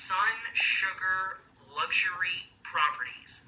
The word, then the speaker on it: SunSugrLuxuryProperties: SunSugrLuxuryProperties.